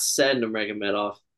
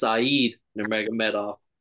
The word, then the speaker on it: Said Nurmagomedov: So I realized, Kev, we've been saying his name wrong this whole time. It's not Said Nurmagomedov. It's Said Nurmagomedov.